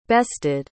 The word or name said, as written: bested